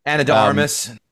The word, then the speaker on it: Ana de Armas: Anna de Armas.